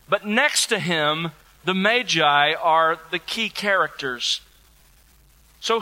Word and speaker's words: Magi: By the way, Magi is the correct pronunciation, so that's why I'm using it with you, in case it sounds strange to you.